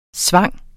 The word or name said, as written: sving